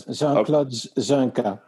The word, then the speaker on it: Jean-Claude Junqua: Jean-Claude Junqua.